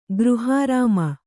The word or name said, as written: ಗೃಹಾರಾಮ